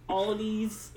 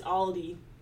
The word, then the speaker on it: Aldi: Aldi's is Aldi.